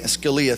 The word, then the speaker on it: Scalia: Scalia.